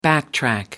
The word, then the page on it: backtrack: American English